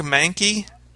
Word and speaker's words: Mahnke: Yeah, and you know, just uh, as a side note, before we move on to our final topic, Doug Mahnke is pronounced Mahnke.